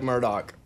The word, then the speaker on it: Murdaugh: It's Alec Murdaugh. – There you go. – Okay, so the Murdaugh murders.